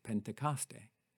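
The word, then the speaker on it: Pentecoste (Πεντηκοστή): 50 days after Easter, the Church celebrates the Christian holiday of Pentecost, taken from the Greek word pronounced Pentecoste.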